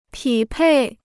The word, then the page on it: 匹配: pǐ pèi